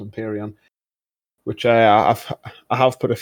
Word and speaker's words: Empyrean: So number two on our list is the Empyrean gameplay. Am I saying that right? – Empyrean.